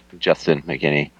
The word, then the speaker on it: McKinney: Yeah, it's Justin McKinney.